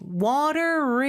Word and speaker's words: water: 네, 여기서는 연음이 water is 이렇게 발음이 돼요. The water is, 그렇죠? 그래서 R 발음으로 연음해 주시고요. R 발음할 때는 혀가 어디에도 닿으면 안 된다는 거 여러분 알고 계시죠? 그리고 water의 T 발음은 real처럼 발음이 돼요.